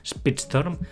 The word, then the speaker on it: Speedstorm: También tenemos el juego de coches Disney Speedstorm, ¿lo he dicho bien? – Sí. – Speedstorm, que llega este invierno.